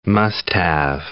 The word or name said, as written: must-have